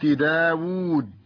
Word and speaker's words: داود: أيضا مما ينطق ولا يكتب الواو في كلمة داود، تكتب هكذا بالرسم العروضي داود، ومثلها أيضا طاووس.